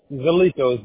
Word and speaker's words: Zannito: Zanlito. Z-A-N-N-I-T-O. – All right, Z-A-L-L-I, C as in Charlie, O as in Oscar.